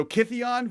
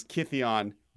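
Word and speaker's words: Kytheon: So Kithion versus Gideon. The reason he's named Gideon is because the accent in Bant pronounces Kithion Gideon.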